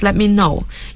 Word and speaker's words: let me know: 那么这位同事还建议他 If you need any help with your report, just let me know. 要注意 let me know. 注意他的表达 T 是不发音的 Let me know.